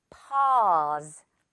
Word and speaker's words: pause: 이 AU를 이거는 pause라고 많이 읽는 것 같아요. 또는 pause라고 많이 하는 것 같더라고요. 자, 그거 아니겠죠, 둘 다? pause, pause가 되겠습니다.